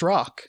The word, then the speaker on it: ROC: Well, maybe some people haven't heard of what that particular vehicle is, the R-O-C. So what is the R-O-C? – Well, it is rock. Pronounced rock. – Sorry, you're right, rock.